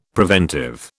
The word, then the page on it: preventive: British English